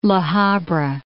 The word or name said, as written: La Habra